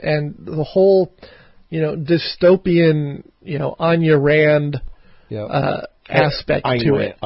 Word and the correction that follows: Ayn Rand: Ayn Rand. – Well, I've heard so many different pronunciations. – They're wrong. I've read up on it. It is definitely Ayn Rand, and you can be for sure because there's a YouTube Donahue clip where he interviews her, and it's definitely Ayn Rand.